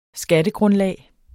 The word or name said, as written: Skattegrundlag